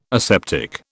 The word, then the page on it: aseptic: British English